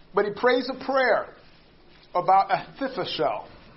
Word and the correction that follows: Ahithophel: Said that wrong. Ahithophel. There we go. There we go. That sounds better.